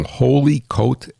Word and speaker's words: Holyoke: It's T-Y-Z-N-I-K. And he's from Holy Coke, H-O-L-Y-O-K-E, Holy Coke, Holy Coke, Colorado.